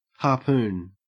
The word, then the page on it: harpoon: Australian English